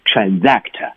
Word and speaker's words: transact: 嗯，好，这个单词金老师发音特别准确啊， transact，那个 s 要发成的是 z，对吧？好，这个词大家来看一下，前面的 trans，您记住了啊，它永远发成 trans，你不要读成 trans 啊。